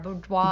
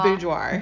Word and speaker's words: boudoir: What was she thinking to now having this elegant, you even do boudoir, how do you say the word? Boudoir. – Boudoir. – Boudoir. It's a French word.